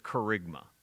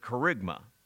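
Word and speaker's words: kerygma (κήρυγμα): You, in the original language, you would pronounce it kerygma. We kind of use an English word that way, not charisma, but kerygma.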